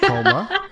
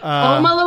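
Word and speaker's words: Houma: We've heard, at least I have heard a lot about HOMA, and I pronounced it correctly. – You did it. HOMA. HOMA, Louisiana.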